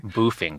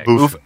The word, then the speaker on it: boofing: I don't know what boffing or devil's triangle mean. I'm sorry. – Boofing. – Boofing.